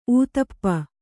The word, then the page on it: ಊತಪ್ಪ: ūtappa; Kannada